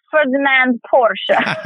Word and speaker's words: Ferdinand Porsche: Oh, probably Ferdinand Porsche.